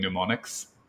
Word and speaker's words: mnemonic: So only boring astronomers find gratitude knowing mnemonics. – I don't like that one because mnemonic starts with an M, but it's pronounced with an N.